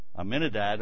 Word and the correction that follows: Ahmadinejad: I can never remember how to pronounce his name. Ahmadinejad, yeah, from Iran.